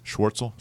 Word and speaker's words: Schwartzel: And then I like the strange name guys like there's one guy, I never pronounce his name, right? Charles Schwartzel. – Schwartzel.